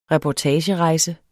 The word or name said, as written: reportage